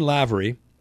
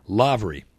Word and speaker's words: Lavery: How does Lynn pronounce it? Is it a long A or a short A? I know everybody wonders about this, so I'll solve it right now. I made a note in my little contacts. Here we go. Lavery. Okay. Not Lavery. Even though there's that damned E, which makes you think it would be Lavery. L-A-V-E-R-Y.